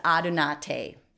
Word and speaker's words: Adunate: My business name is Adunate, A-D-U-N-A-T-E. And I like to say that often. It's unique, yes, but it promotes conversation, and that's what we need. That's what marketing is. And Adunate is Latin for unite.